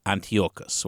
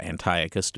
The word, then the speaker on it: Antiochus: Um, and one of their leader, the, the, the leader at one point was a king named uh, Antiochus or Antiochus, depending on which pronunciation you prefer.